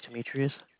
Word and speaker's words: Demetrius: My name is Demetrius. – How do you spell it? D-E-M. Can you spell it for me? – D-E-M-E.